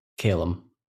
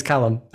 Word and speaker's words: Callum: So I guess my name would be Callum here, but it's actually Callum. So I guess my name is pronounced in the American fashion. – Yeah. So it's Callum. – No, it's Callum.